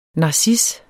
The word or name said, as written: narcis